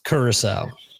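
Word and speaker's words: Curaçao: It looks cool, but then I saw these other ones like that, the Caraco black marble, like that looks sick. Like, man. – Just to fill you in, that's pronounced Curacao.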